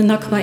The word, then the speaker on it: Nakba: En de Nakba is 77 jaar geleden.